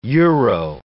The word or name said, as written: euro